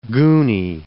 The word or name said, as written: gooney